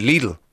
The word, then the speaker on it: Lidl: We rang somebody in Germany and asked them, how do you pronounce the name of your supermarket? And the lady said Lidl.